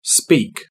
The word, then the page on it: speak: British English